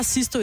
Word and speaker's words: Sisto: Ja, jeg kan ikke udtale det. Chisto? Chisto? – Chisto? S-I-S-T-O. – Nå, han hedder bare Chisto.